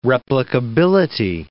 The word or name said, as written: replicability